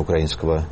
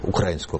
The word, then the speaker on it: украинского: И сегодня косвенно обозначается присутствие того игрока, которого не хватало до сих пор в рамках обсуждения минских договоренностей и перспектив урегулирования украинского, вот я, может, мне Виктор поправит, я все время мне поправлю, украинского, правильно говорить. – Украинского.